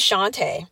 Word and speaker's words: Shawnta: My full name is Shantae, but it's spelled S-H-A-W-N-T-A, and normally gets mispronounced.